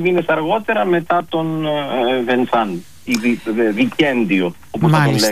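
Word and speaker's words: Vincent: Δεν είναι τυχαίο ότι ο Τεό και ο Βενσάν, ο Βινσέν όπως τον προφέρει κανείς, βρίσκονται στον χώρο, τον ταφικό χώρο δίπλα-δίπλα.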